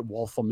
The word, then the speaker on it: Waltham: He goes, I know what Waltham is. I said, oh, it's actually pronounced Waltham.